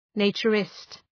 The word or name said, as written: naturist